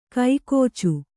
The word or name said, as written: ಕೈಕೋಚು